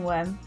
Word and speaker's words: Wyrm: It is a boring worm, but I had to think of it on short notice. My worm of the week is Worm, which is a worm that is spelt W-Y-R-M.